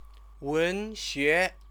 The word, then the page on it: wenxue: Chinese